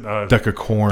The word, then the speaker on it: decacorn: Yeah, so a decathon, is that how you pronounce it? – Decacorn. – Decacorn. – Yes.